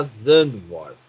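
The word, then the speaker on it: Zandvoort: Ah, a Rússia, se for para Igora, parece que vai colocar uma curva inclinada lá Zandvor, como você bem me ensinou a pronúncia correta.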